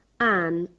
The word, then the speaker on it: Anne: Anne, spelt A-double-N-E.